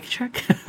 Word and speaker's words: Czech: Mike Czech? – Yeah. How do you spell that? – Yes. C-Z-E-C-H, like there you Czech go. Republic.